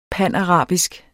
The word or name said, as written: panarabisk